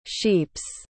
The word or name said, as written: sheeps